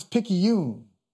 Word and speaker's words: Picayune: The New Orleans Times Picayune. Is that how you say it? – That is how you say it.